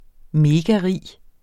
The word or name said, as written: megarig